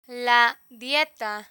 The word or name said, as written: la dieta